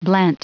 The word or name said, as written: blent